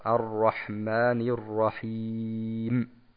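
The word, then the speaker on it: Ar-Rahmanir-Rahim (الرحمن الرحيم): dass man hier nicht in der Verlängerung das Runna, also diesen nasalen Ton, dieses Summen, durchführt, sondern erst am Ende ganz kurz, also Ar-Rahmanir-Rahim, ganz kurz am Ende das Mim aussprechen. Dies wäre die korrekte Aussprache.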